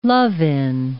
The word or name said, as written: love-in